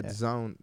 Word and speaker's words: DAZN: Sorry, I keep calling it DAZN. It's Zone. – I did it for a long time, too. And I don't know how anyone thought that DAZN should be pronounced Zone.